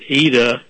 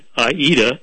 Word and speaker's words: Aida: Since 2005, Rich lived in the, I believe it's pronounced Aida refugee camp. It's spelled A-I-D-D-A. Opera lovers will pronounce it Aida. But the composer was Italian, and we're talking about Palestine.